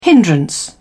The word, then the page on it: hindrance: British English